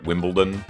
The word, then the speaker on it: Wimbledon: Wimbledon? – Yes. – Well, I know a lot of people say like Wimbledon. It's like it's Wimbledon, D-O-N.